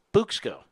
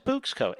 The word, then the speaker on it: Bouqs: Now, what is Buxco? It's spelled B-O-U-Q-S, like bouquet, the beginning letters, five letters of bouquet. Bux, B-O-U-Q-S, as in bouquet of flowers.